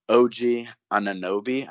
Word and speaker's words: OG Anunoby: We've got Lowry at the one, Norman Powell at the two, OG Anunoby.